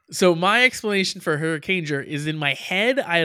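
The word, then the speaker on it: Hurricaneger: Kieran says, Hurricanger is just hurricane plus jur, as in ranger, not whatever the hell you said it was.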